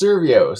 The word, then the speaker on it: Survios: So you work how do you pronounce the company? Survios? I always get it wrong. – It's Survios. So they're a VR company.